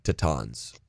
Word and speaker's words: Tetons: Dude, so you got to tell Michelle Houston that, like, hey, listen, it's actually pronounced Tetons. And they're like, it's like, if you don't say that in Wyoming, people get, like, really offended.